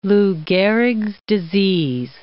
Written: Lou Gehrig's disease

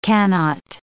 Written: cannot

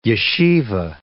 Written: yeshiva

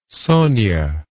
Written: sonya

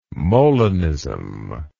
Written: Molinism

